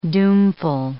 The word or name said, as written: doomful